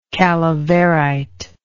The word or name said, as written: calaverite